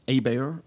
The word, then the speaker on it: Hebert: So I grew up with Thibodeaux and LeBlancs, and if you see it spelled H-E-B-E-R-T. Hebert, but it's pronounced Abear, and so typically somebody that's not from kind of Cajun country would, stumble over that.